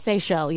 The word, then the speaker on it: Seychelles: Have you ever heard of the, I believe it's pronounced, it pronounced Seychelles? – Seychelles, yeah. – The Seychelles? – Yeah. – The island, islands? – S-E-Y-C-H-E-L-L-E.